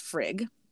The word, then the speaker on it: Frigg: Her name is pronounced Frigg, although there is a couple G's in there. It's not G-G-G-G. It's just Frigg.